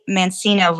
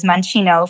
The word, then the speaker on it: Mancino: So you can send me an email at Mancino. I say Mancino versus Mancino for those who aren't familiar with the Italian pronunciation.